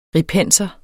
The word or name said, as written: ripenser